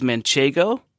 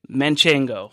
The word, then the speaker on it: Manchego: Manchego is a cheese from La Mancha, so it's Manchegan, like Don Quixote is Manchegan, and the full name of the cheese is Manchego, Manchego. – No N in the middle.